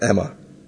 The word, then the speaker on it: Aemma: Is it Emma? Aemma? – I think it's kind of pronounced Emma.